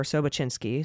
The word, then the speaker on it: Sobocinski: So, just to name a couple here, we have, of course, Sobocinski or Sobachinsky, excuse me, however, uh, I, f- I feel like you pronounce it Sobachinsky. Somebody sent me a correction.